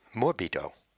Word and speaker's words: morbido: And I guess the way to do that is to give you the seven-letter word, which, it so happens, rhymes with libido. And that word is morbido, M-O-R-B-I-D-O.